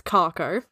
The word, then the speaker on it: kharcho: So instead of Clark, it's Kako. – How do you spell that? – K-H-A-R-C-H-O.